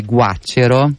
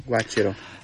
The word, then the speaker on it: Guaccero: Tra l'altro io avevo anche sbagliato il suo nome, adesso lui mi ha detto come si pronuncia, Giovanni Guaccero. – Guaccero.